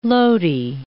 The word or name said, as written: loti